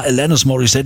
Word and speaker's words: Alanis Morissette: Alanis Morissette? Was ist das? Alanis? Wie spricht man die denn aus irgendwie, ne? Bis dann irgendwann hieß, ja, Alanis Morissette, die kommt aus Kanada, englische Aussprache und so.